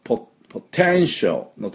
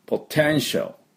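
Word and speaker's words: potential: で、このポテンシャルのところの、 TIAL、最後のところね、ショーのところは、 A の音はほとんど発音しません。ポテンシャル。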